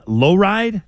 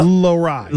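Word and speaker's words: Lohrei: Okay, so we have the Patra, who's seems like he's not going anywhere, but there's another new guy, Mason Lowride. Did I say his name right? – Yep. – Lowride.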